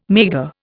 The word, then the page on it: maigre: Indian